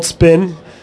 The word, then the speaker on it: Sphinn: It's called Spin. It's pronounced Spin, not Spin, but it's spelled SPHINN.